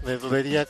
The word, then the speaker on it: Nerdulería: Facebook, Nerdulería, Twitter, arroba Nerdulería, YouTube, es Nerdulería con acento en la I.